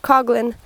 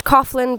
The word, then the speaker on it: Coughlin: It's spelled C-O-U-G-H-L-I-N. My father and I pronounce it Coughlin. My grandparents pronounce it Coughlin because it was just easier than correcting everybody, I assume. And my great-grandfather, who is Irish, said Coughlin.